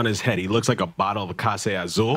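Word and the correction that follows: Clase Azul: Even though he pronounced it wrong, he's back. Yeah. He said Case, not Classe, but it was so good.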